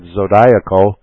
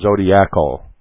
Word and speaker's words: zodiacal: It is some people pronounce that zodiacal, but since zodiac is an English word and you add the A-L after that, it should be pronounced zodiacal.